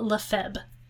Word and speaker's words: Lefebvre: His last name is spelled L-E-F-E-B-V-R-E. As far as I can tell, in French, the B is silent, but I think that he was pronouncing the B when he said his name.